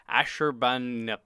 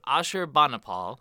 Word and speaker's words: Ashurbanipal: The Library of Ashurbanipal. I butchered that. Help me. – The Library of Ashurbanipal. – Thank you.